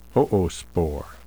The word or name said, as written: oospore